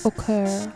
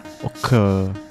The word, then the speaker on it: Ocoeur: How would you pronounce that artist? – I would say Occur. – Occur.